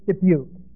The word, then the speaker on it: Dubuque: How do you pronounce that? Dubuque. I knew how to pronounce that.